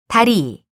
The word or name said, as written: 다리